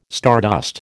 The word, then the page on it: stardust: American English